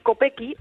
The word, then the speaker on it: Kopecky: Kopecky, el apellido, Lotte Kopecky.